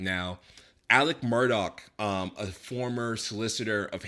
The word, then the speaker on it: Alex: As soon as you type Alex, it's spelled with an X, but it's pronounced Alec.